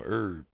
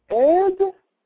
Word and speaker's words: herb: herb? – I don't have no herb. – You don't pronounce the H? – What the hell you call it?